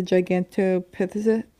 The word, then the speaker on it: Gigantopithecus: So the Yeti is described as bipedal, and most scientists believe that the Gigantopithecus, is that how you say it? – Mm-hmm. You got it.